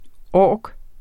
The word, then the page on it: ork: ˈɒˀg; ˈɒːg